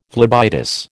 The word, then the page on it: phlebitis: American English